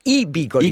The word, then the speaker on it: bigoli: qui s'appelle le bigoli. – Les bigoli. I-bigoli. – I-bigoli. I-bigoli. Absolument, j'ai mis l'accent tonique au mauvais endroit.